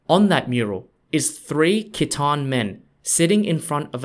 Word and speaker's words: Khitan: Liao spelt L-I-A-O. The Liao dynasty was ruled by an ethnic group called the Khitan, spelt K-H-I-T-A-N, and is pronounced Qi Dan in Chinese.